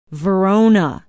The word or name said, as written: verona